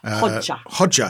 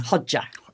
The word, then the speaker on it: Hoxha: Hoxha. – How do you say Hoxha? – Hoxha. – It rhymes with Todger.